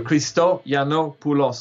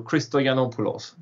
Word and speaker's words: Christoyannopoulos: If you break it down, it's three. It's easier. Christoyannopoulos. So Christoyannopoulos.